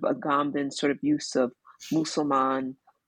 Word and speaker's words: Musulman: Am I saying it correctly? Okay, Musulman. Mm-hmm.